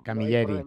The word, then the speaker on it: Camilleri: No sé si he dicho bien tu apellido, porque ¿lo he pronunciado bien o no? – Sí, es Camilleri, pero bueno. – Camilleri, no, me he pasado de listo, he pensado en italianizarlo.